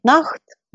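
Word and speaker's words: nacht: На голландском это звучит «нахт» – «ночь». Буквы «сх» произносятся в современном голландском как «х».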